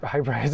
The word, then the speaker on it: Hyperice: Oh, I guess if I said it like the correct way, like Hyperice. If I like made a big deal of saying hyper, that could be another one too.